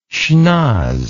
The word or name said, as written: schnoz